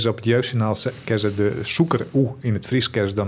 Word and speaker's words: sûker: dat die even zei van, nee, zoeker, zoeker. Ja. Even goed uitspreken.